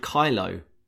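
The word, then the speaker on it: Kialo: It's a website I discovered recently called Kylo, which I think might be named after the Star Wars character.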